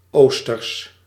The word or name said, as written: East